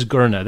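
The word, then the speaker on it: Gurnah: The last name is Gurna. That one I do know. G-U-R-N-A.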